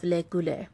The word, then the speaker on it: Le Goulet: A year after becoming king, John and Philip met to sign a Treaty of Le Goulet. I think that's pronounced like Robert Goulet.